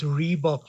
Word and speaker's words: Rehbock: Aubrey, it's Reebok, like the running shoe. – Okay. Because I see yours is spelled R-E-H, and the shoes is R-E-E.